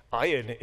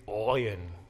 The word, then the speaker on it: ayin: Ayin is pronounced, one grammatical book refers to this letter as a pronunciation guide, the sound of vomiting. So it's not ayin, it's oyin. You hear that? Oyin.